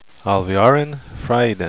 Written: alviarin

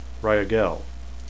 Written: rhyagelle

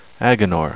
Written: aginor

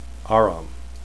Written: aram

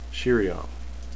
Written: sheriam

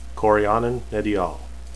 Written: corianin

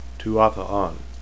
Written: tuathaan

